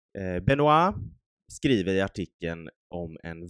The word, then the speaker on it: Benoit: Eh, den är från juni 2011 och är skriven av en kille som heter Benoit Denisette Lewis jag tror att det uttalas Benoit, för det stavas B-E-N-O-I-T. – Mm. Det låter korrekt.